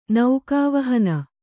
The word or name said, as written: ನೌಕಾವಹನ